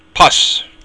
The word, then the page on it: pus: Irish